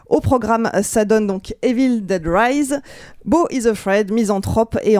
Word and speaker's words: Evil Dead Rise: Je sais que je le prononce pas bien. Quelqu'un peut le dire correctement à ma place Moi, je dirais Evil Dead Rise. – Merci. Evil Dead Rise, sorti en salle le 19 avril.